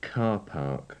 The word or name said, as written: car park